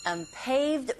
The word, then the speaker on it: unpaved: That is, unpaved road on the cliff. Unpaved. 끝까지 발음하는 게 아니라 pay, 에, 이 부분에 강세 주시고 road, 자주 등장하지만 이게 만만치 않은 발음이죠.